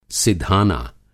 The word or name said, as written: सिधाना